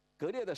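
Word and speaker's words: 盖聂: 荆轲到处漫游，到了榆次，这我们知道从南往北一进入山西到榆次，在榆次这里呢遇到一个真正的剑客，叫做格烈，这个盖字读作格烈。他和格烈谈论剑术，这格烈听不下去了，就怒目而视。荆轲一看不得了，跑了。荆轲出去以后格烈的手下就说，你瞪人家一眼不走了，是不是把他请回来再谈谈？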